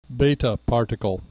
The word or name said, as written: beta particle